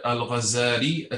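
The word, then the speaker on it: al-Ghazzali: Abu Hamid, Muhammad ibn Muhammad ibn Muhammad al-Ghazali al-Tusi. And actually, the better way to say his name, there should be a shadda on the zai. This is like a common mistake.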